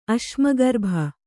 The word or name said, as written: ಅಶ್ಮಗರ್ಭ